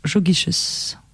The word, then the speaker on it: Jogiches: Un texte de Rosa Luxembourg, donc, qui a été adressé à Léo Jogiches.